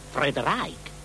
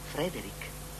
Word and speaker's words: Frederick: Allora dice anche Friedreich. – No, Frederick.